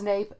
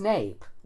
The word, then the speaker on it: nape: He has nape as nape, long A, but then he says that Webster said that the pronunciation nap, very common in the United States, is generally regarded as colloquial or dialectal. – I'm still sticking with the fact that in Latin it's N-A-P-P, in medieval Latin it's N, I just looked at my medieval Latin dictionary, it's N-A-P-P-A in Latin.